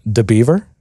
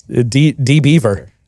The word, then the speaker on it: DBeaver: And so I don't know if you pronounce that like DeBeaver or DBeaver, but it's DBeaver. DBeaver. – Yeah, I'd say DBeaver.